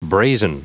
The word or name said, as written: brazen